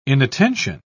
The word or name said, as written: inattention